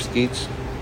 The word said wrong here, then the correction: Skeates: You say Skeets, but you say it wrong. – S-K-E-A-T-E-S. – S-K-E-A-T-E-S. Skates. – Yeah. – Oh. He pronounced it Skates.